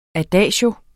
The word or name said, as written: adagio